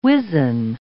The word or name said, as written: wizen